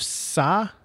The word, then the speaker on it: Tsai: New York Liberty owner, let me say his name correctly. It's T-S-A-I, Joseph Tsai, I would think it's Tsai, yes.